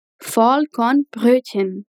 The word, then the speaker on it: Vollkornbrötchen: Como era pão integral, Vollkornbrot fica Vollkornbrötchen. Essa palavrinha pode ser chata para pronunciar, então vamos repetir ela algumas vezes. – Vollkornbrötchen. Capricha bem no CH fraco, aquele mesmo que usamos para falar ich.